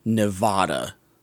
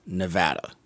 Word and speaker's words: Nevada: Multiple times in this movie, they mispronounce Nevada. It's a pet peeve of mine. It's not Nevada. It's Nevada.